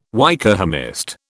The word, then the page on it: Wykehamist: British English